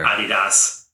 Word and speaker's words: Adidas: Adidas. yeah. That's actually how it's supposed to be pronounced, right? Yeah.